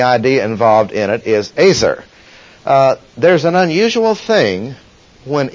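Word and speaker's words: Azer (עֵזֶר): And Azer should really be pronounced. Azer is a word that means helper.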